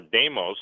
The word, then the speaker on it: Deimos: Deimos or Deimos, some people pronounce it, means demon.